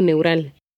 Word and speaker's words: neural: Los defectos del tubo neural. – And the word neural in Spanish has a diphthong that can be hard to pronounce. Neural.